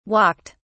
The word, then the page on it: walked: British English